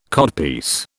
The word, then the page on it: codpiece: British English